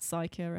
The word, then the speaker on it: Suiker: Yeah, S-U-I-K-E-R. So if you don't know how to say it, because I had to actually ask Nicole how to pronounce it because I'm a retard. And if you're in England, just think of Biker Grove, but say psycho instead. – Yeah, instead of biker, psycho.